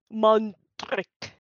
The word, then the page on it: Mondrak: Phyrexian